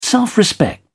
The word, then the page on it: self respect: British English